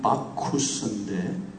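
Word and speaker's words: Bacchus: 디오니소스는 헬라 말이고 로마식 발음은 바쿠스인데 그 동아제약에 그 이 바카스 이름을 붙인 그 회장이 유학 중에 이 바쿠스를 바카스라고 해서 이름을 지었다는 것을 제가 읽은 적이 있습니다.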